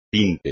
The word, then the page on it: tinte: espagnol